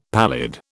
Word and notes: pallid: British English

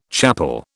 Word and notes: chappal: British English

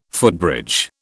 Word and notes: footbridge: British English

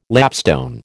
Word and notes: lapstone: American English

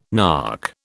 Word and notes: nark: British English

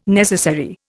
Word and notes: necessary: Indian

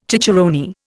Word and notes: cicerone: Indian

